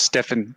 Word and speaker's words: Stiofane: It's Irish. It's pronounced Stefan, but oh, Irish.